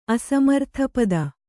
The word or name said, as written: ಅಸಮರ್ಥಪದ